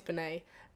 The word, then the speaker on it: Benee: I think it's Binet. And she's like, she's Kiwi. B-E-N-E-E.